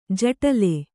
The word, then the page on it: ಜಟಲೆ: jaṭale